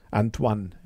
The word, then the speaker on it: Antoine: Ich hatte zwar kein Französisch, aber heißt es nicht Antoine? – Antoine, ja stimmt.